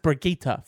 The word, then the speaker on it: Brigitte: It's it, it's spelled Bridget. It's Bridget, but it's pronounced Brigitta for some reason.